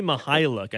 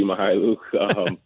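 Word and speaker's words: Mykhailiuk: He's been someone who has really interested me so far this season is Svi, and I always fear that I'm mispronouncing his last name, but Svi Mihailuk, I believe is how you pronounce it.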